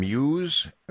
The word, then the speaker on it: Musse: And this is Deputy, I may mispronounce, did you say Deputy Brandon Muse? Am I saying that right? – Yep. – M-U-S-S-E, Deputy Brandon Muse, receiving the Citizen of the Month Award.